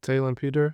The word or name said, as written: Taelon Peter